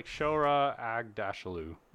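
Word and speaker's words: Aghdashloo: And I was it's Shora Ag-Dashlu. – Oh, okay.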